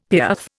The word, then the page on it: piaffe: Indian